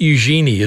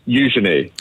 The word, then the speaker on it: Eugenie: Eugenie, not Eugenie, as we've been saying. Eugenie, apparently, I suppose.